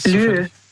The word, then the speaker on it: Lieu: Lieu. – Lieu. Lieu? – Also Lieu, das schreibt man, also L-I-E und dann U.